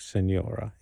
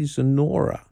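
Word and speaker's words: señora: But he doesn't pronounce it like senora. His Spanish is a little crooked, and he says, hey, Zenora, yeah.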